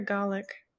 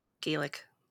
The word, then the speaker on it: Gaelic: So is it Gaelic or Gallic? – Gaelic. – Hey Siri, how do you pronounce Gaelic? Yeah, it's Gaelic. Oh no, it says Gallic. – What? – It says both of them. It says both of them. – Oh, so both are right.